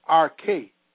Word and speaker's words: arche: The Greek word for principalities is archi, pronounced arche, meaning a chief.